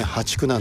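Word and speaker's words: 淡竹: でこれからは破竹、淡い竹と書いてね、破竹なんですけどね、淡水の淡、淡いですね、これに竹、破竹。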